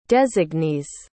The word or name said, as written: designees